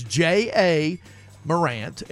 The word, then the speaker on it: Ja Morant: It's J.A. Morant, and he pronounced it Ja Morant, and he's, like I said, a lottery pick in the NBA draft.